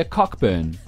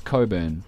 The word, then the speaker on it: Cockburn: So Olivia Wilde's birth name is Olivia Cockburn. – Oh. – Mm-hmm. Oh. – Oh, no, wait, hold on. I'm being told it's pronounced Coburn. – Oh, okay. Okay. – Okay, that's better. – Okay. C-O-C-K-B-U-R...